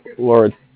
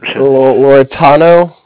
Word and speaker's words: Lauretano: L-A-U-R-E-T-A-N-O. Sign Group Incorporated? – Oh, Loretano. – Yeah, that's it. – Loretano? – Loretano Sign Group Incorporated.